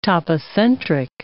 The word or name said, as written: topocentric